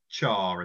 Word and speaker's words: Chang: it's spelt Chang, but they pronounce it Char in the film.